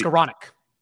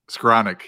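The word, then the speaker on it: Skowronek: Is it Skornik? I don't know how to pronounce his name. – Seven uh, for eighty. Skornik. – Skornik.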